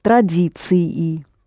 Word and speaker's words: традиции: And both of them are pronounced «традиции».